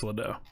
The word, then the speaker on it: Ledoux: It was Chris Ledoux.